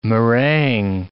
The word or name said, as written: meringue